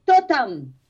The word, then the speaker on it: ktotam: K-T-O-T-A-M. – Say that again? – K-T-O-T-A-M. – Ah, totem. – So you don't pronounce the K. The K silent.